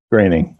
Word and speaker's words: Groening: I always mispronounce his last name. – Groening. That's okay.